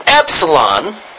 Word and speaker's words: Epsilon: Now, this Epsilon is pronounced as the E in Met, all right?